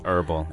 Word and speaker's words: herbal: 所以你说它是一个 herbal, 或者你读的是 herbal. – Yes, herbal. – Herbal,、yeah. H-E-R-B-A-L, 但有的时候你也可以那 H 不发音,对吧、 yeah.